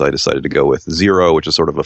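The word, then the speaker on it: Xero: I will start with Zero, spelled with an X.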